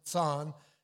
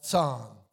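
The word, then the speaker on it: Ţon: You know, I go to Romania, and Joseph Tson, it's T, we spell it T-S-O-N and pronounce it Tson, but in the Romanian way of writing it, it's just T with a comma, O-N, and you pronounce that Tson, Tson.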